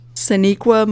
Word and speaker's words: Sonequa: It's pronounced Sonequa Martin Green.